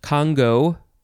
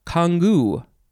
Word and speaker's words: Congou: Okay, next is, it can be pronounced Congo or Kangu. But in either case, you have to emphasize the first syllable. Congo, Kangu, spelled C-O-N-G-O-U. Noun from 1725.